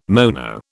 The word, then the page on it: mono: British English